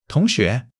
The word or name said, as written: 同学